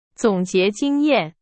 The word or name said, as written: 总结